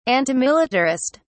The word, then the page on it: antimilitarist: British English